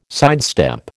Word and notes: sidestep: American English

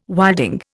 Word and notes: wilding: Indian

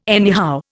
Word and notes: anyhow: Indian

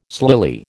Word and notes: slily: American English

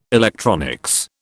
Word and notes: electronics: British English

